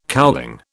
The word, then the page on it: cowling: British English